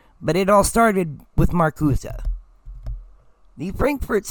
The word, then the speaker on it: Marcuse: It's spelled Marcuse, but pronounced Marcuse, as far as I've been able to find out.